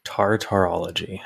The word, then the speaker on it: Tartarology: Tartarology. I'm going to see how that's spelled without skimming any farther below. – T-A-R, T-A-R, and for what it's worth, that was the Greek hell, Tartar, or Tartarus.